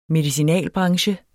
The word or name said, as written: medicinalbranche